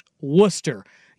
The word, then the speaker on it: Worcester: It is spelled Worcester, and it is pronounced Worcester.